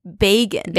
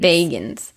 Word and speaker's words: Bagans: Also, one thing that I realized is we've been saying Zach Bagans' name wrong the entire time. – Yes, I know. – It's Bagans. – Bagans.